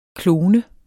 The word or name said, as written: klone